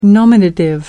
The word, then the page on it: nominative: American English